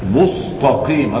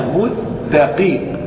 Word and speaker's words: مستقيم: رقق ولا تجعله كالصاد في كلمة مستقيم، فلا تقل مستقيم، مستقيم هذه سين. أما لما نطقت بالسين بل أتيت بالسين صادا مستقيم، هذا خطأ، ولكنها مستقيم، مستقيم، مستقيم، وهكذا.